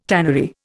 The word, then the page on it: tannery: Indian